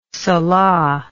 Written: salah

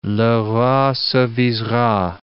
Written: le roi s'avisera

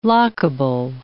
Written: lockable

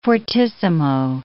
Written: fortissimo

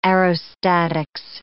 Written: aerostatics

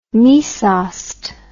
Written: mysost

